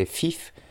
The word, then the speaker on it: Fief: ça s'écrit F-I-E-F. E alors soit je fais FIF, comme euh, un, bandit en anglais, mais normalement ça s'écrit pas comme ça. Sinon on le fait à la française, FIEF, voilà, on va dire FIEF Production.